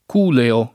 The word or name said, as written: culeo